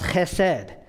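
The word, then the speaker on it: chesed (חסד): It's a Hebrew word that's pronounced chesed, and it means covenant-keeping, unconditional love.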